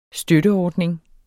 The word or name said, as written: støtteordning